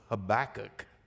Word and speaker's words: Habakkuk: And we're going to be in the book of Habakkuk. Okay, H-A-B-A-K-K-U-K, if you have never known how to spell that. But it's Habakkuk.